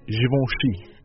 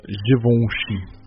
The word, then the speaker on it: Givenchy: So another brand I heard is really famous and popular in China is Givenchy.